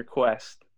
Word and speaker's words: Kuest: There's a K in your Kuest.